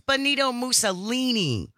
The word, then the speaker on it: Benito Mussolini: It's Benito Mussolini.